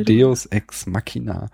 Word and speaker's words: Deus Ex Machina: Ist nicht Machina? – Ja, Deus, ich hab's hessisch weich ausgesprochen schon. Deus Ex Machina.